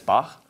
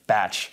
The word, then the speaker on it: Bach: in a family concert, and I couldn't even pronounce the composer J.S. Bach. – I, at that age, pronounce it batch.